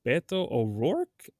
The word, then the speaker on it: Beto O'Rourke: and Beto O'Rourke? How do you say that one? – Mm-hmm. That you said it right. Yeah. – Oh, I said it in Spanish.